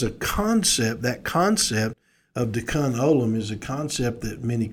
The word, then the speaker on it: Tikkun Olam: Now, for my Jewish friends that are listening, you know that my pronunciation of Tikkun Olam is I was raised on phonetics and understanding, so I don't say it the way the Jewish, but for me, it's spelled T-I-K-K-U-N, and then the next word is O-L-A-M, so I call it Tikkun Olam.